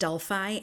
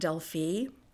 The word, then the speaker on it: Delphi: And just so you know, it is pronounced Delphi and not Delphi, Delphi, Indiana.